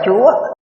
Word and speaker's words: chúa: Đây là thiên chủ, chữ chủ này đọc là chúa, chủ là đứng đầu, chúa là đứng đầu, vua cũng đứng đầu.